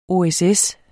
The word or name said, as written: OSS